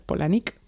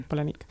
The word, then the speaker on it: Palahniuk: Nos pusieron en una entrevista conjunta con más gente todos a competir por hacerle pues preguntas a Chuck Palahniuk, que de hecho se pronuncia Polanik, pero bueno. – Ah, muy bien. – Polanik.